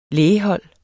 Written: lægehold

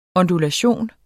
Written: ondulation

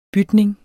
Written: bytning

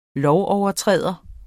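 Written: LOVOVERTRÆDERES

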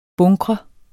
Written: bunkre

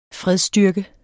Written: fredsstyrke

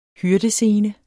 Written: hyrdescene

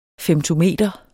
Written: femtometer

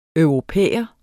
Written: europæer

